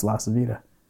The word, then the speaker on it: LaCivita: it doesn't matter because that's not even how you say your last name. So I'm like 23 at this point. He's like, no, you say it. It's pronounced LaCivita.